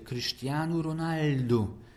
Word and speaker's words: Cristiano Ronaldo: Moja koleżanka z Wydziału Neofilologii, która jest specjalistką w zakresie języka portugalskiego, jeśli dobrze pamiętam, mam nadzieję, że nie przekręciłem, powiedziała mi, że imię pewnego znanego piłkarza i nazwisko wymawia się Cristiano Ronaldo. Cristiano Ronaldo.